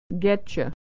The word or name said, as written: getcha